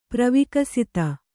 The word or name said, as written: ಪ್ರವಿಕಸಿತ